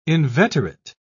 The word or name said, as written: inveterate